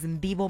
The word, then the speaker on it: Ndivho: My name is Ndivho Makwanya. It is spelled out as N-D-I-V-H-O.